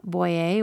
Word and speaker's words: Boyer: Boyer, which is my last name. It's spelled B-O-Y-E-R.